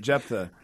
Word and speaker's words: Jephthah: Yeah, we had a little discussion of how to pronounce Jephthah earlier, but I think that's it, Jephthah.